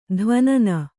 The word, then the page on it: ಧ್ವನನ: dhvanana